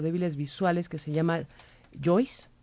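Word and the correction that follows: JAWS: caballeros. – Joss. – Se escribe J de Julieta, A de Alfa, W de Whisky, S.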